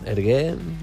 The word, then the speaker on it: Hergé: Com haurien de pronunciar? Ergué, així? – Supos que sí. – Sí. – Ergué, direm així.